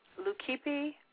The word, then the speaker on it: Leukippe: India's mare mother, Serenayu, mare-headed Demeter, or Cretan, I am chopping these words up, so I'm sorry, or Cretan Leukippe, it's spelled L-E-U-K-I-P-P-E, the quote-unquote white mare, M-A-R-E, whose priests were castrated and wore female dresses.